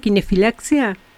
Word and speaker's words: kinefilaxia: dentro de la kinesiología que es la kinefilaxia. ¿Es así? ¿Está bien pronunciado kinefilaxia? – Sí, es así.